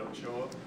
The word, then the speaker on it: Ochoa: My name is David Ochoa. D-A-V-I-D-O-C-H-O-A.